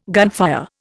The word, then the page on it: gunfire: Indian